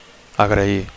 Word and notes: agrair: Catalan

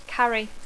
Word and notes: carry: English

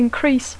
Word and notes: increase: English

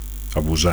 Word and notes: abusar: Catalan

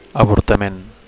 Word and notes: avortament: Catalan

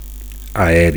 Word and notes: aeri: Catalan

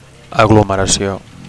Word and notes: aglomeracio: Catalan

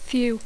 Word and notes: few: English

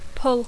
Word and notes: pull: English

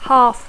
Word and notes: half: English

